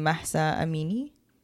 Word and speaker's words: Mahsa Amini: From what I've read and done my research on, I think you pronounce her name Mahsa Amini. – I'll say yes.